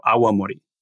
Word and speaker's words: awamori: And it's a four-syllable word rather than a two-syllable word. So it's a bit more cumbersome, but I think maybe a little easier to say correctly. It's awa plus mori, so awamori.